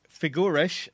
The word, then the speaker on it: Figueres: Figueres. I spell it F-I-G-U-E-R-E-S.